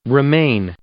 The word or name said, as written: remain